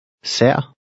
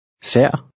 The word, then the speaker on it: sær: The middle letter is an AE combination. It's one of 3 additional letters in the Danish alphabet. Repeat number 9 after the speaker, paying attention to the pronunciation. – Sær.